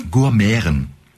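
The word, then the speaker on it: Gurmiaren: Gourmeren. – Gourmeren. – Also G-U-R, okay. Und dann M, das Schöne, M-I-A-R-E-N, das wird wie Ä ausgesprochen.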